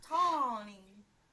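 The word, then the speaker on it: Tawni: So it might be hard to pronounce for people that aren't from like Midwest, but it's Tawny. It's T-A-W-N-I.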